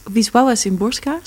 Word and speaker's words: Wisława Szymborska: Dat is een Poolse dichteres, Wisława Szymborska, spreek je het uit.